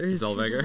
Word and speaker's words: Zellweger: Zellweger.